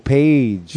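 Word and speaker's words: Page: Jimmy Poggy. – He's the guitarist for Jimmy Poggy? – No, Jimmy Poggy. Maybe I'm pronouncing it wrong. – Oh, Paige.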